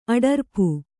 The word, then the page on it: ಅಡರ್ಪು: adarpu